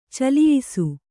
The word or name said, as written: ಚಲಿಯಿಸು